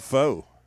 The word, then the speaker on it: pho: He, I thought it was like, called pho. – Most a lot of people do. It's pronounced pho.